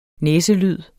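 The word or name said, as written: næselyde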